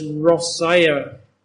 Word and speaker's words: Rossiya: And even today, if you're in Russia, they pronounce Russia as Rossiya.